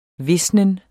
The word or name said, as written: visnen